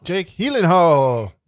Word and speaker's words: Gyllenhaal: Jake Gyllenhaal.